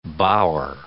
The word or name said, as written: bower